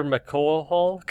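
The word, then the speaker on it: McCulloch: Christopher McCohol.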